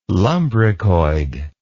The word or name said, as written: lumbricoid